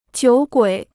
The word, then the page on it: 酒鬼: jiǔ guǐ